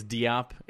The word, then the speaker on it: Diop: In honor of Kata Bates Jop. – That's okay. – Or sorry, Kata Bates Diop is how Diop. He wants it to be pronounced.